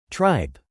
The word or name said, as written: tripe